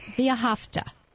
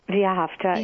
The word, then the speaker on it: Ve'ahavta: yeah, it's a Hebrew word, and it means "you shall be loved." Yeah, Ve'ahavta. Ve'ahavta, yeah. and it's an organization that is about repairing the world one action at a time.